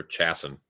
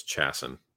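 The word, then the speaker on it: Chiasson: Stephanie Egger's pretty good, and Macy Chason, or Chasson, sorry. I still want to pronounce her name correctly, but it's Chasson, whatever.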